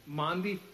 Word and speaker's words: Maundy: I call them Mandy, but that's not how they're pronounced. Maundy Thursday practices, which celebrates the Last Supper event.